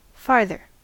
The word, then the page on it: farther: American English